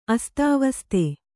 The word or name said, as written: ಅಸ್ತಾವಸ್ತೆ